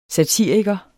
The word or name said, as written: satiriker